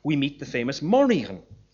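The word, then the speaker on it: Morrígan: Now, the first syllable of that word is originally short, but in Middle Irish, perhaps encouraged by folk etymology, the first syllable becomes Morrighan, great queen, instead of something like nightmarish queen.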